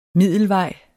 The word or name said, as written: middelvej